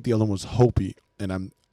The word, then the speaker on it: Hopi: It is she is Navajo and Hopi. I hope. – Yeah, I yeah, hope, you said it right.